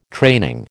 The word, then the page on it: training: American English